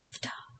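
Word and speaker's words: Ptah: If you no, Ta. It's P-T-A-H, but the P is silent. – Ta. – It's Ta.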